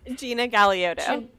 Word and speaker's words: Gina Galeotto: Gina Galeotto. It's a hard one. – You said gelato. – It's never been pronounced right in my life.